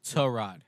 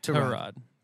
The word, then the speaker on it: Tyrod: With By the way, it's Tyrod. – Tyrod. Apparently, we've all been saying it wrong for his entire career.